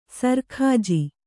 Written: ಸರ್‍ಖಾಜಿ

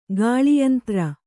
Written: ಗಾಳಿಯಂತ್ರ

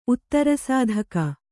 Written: ಉತ್ತರಸಾಧಕ

